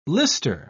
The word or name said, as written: lister